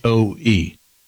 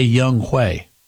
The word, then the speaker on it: Younghoe: By the way, his first name is spelled Younghoe. And they say Young Huey. That is Young Ho. There is no way that is not Young Ho. It's Young Ho Koo, K-O-O.